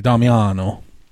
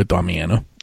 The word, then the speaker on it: Damiano: Well, are we talking from Italy or how we pronounce it today? – Oh, dude, go Italy all the way. – So if in Italian, it'd be Damiano. – I like it. – But we go Damiano.